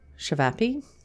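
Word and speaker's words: ćevapi: Shivapi. Am I saying that right? – Shivapi? – Yep.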